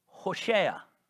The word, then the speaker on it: Hoshea: Did you mispronounce that? Did you suddenly sneeze in the middle of trying to say Joshua? No, I did not. It is Hoshea.